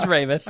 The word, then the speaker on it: Ramis: Ramey? Is it even the S is silent? – No, it's I don't know, man. – It's Ramis.